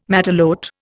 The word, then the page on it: matelote: Indian